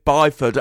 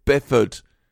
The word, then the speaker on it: Byford: Because Simon just called the lead singer Biff Byford, and I always assumed it was Biff Bifford. What is it? – I think it's Bifford, you know. Although it is spelt Byford.